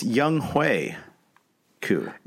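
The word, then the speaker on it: Younghoe Koo: The Atlanta kicker, it's Young Hui Koo. – Yeah, I knew that the whole time. – Yeah. I mean, it's spelled Young Ho, but I was very disappointed to hear that. For whatever reason, I had never heard it pronounced.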